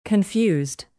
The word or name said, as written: confused